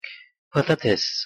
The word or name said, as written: patates